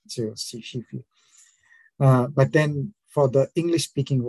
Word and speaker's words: Skyfri: So one other thing I had to tell you that Skyfree actually is a Norwegian word in a sense. So it's pronounced as Shifi. – Oh, she-free.